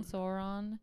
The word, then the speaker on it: Sauron: Well, also the weirdest part about all of it is that like you sort of, I don't know, like Sauron. Is that how you say it? You freaking know. – Sauron is just this big magical eyeball on a tower.